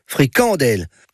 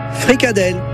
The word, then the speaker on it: fricadelle: Alors, innadé, ils disent fricadelle, et innadésote, ils disent fricandelle. En bon François, en tout cas. La bonne prononciation francophone en France ou en Wallonie, c'est fricadelle. Fricandelle chez les flahutes.